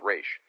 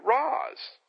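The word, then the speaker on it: Ra's: So that's Raish. Then he says to make things even more complicated, a couple weeks before, Kevin Smith had an interview with Neal Adams. Neil, however, pronounced it Roz. So to recap, Denny O'Neil and Julie Schwartz, uh, the creators of the character, say Raish. Neal Adams, creator of the look of the character, pronounced it Raish. So I suppose it comes down to who you want to believe.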